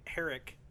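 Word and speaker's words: Herek: So Steve Herrick, I think is how you pronounce his name. – Yeah, it is.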